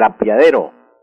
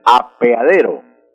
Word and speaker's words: apeadero: Las muchachas dicen el apiadero, y no es el apiadero, sino el apeadero, con E. Entonces hay que hacer esa aclaración. Y apiadero tiene el error de pronunciación.